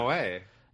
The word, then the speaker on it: Noé: How do you say his last name? That's something I feel like I fuck up all the time. – Noé. Gaspar Noé. – Yeah, I say I've said Jasper No so many times.